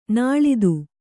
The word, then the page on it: ನುಲಿತ: Kannada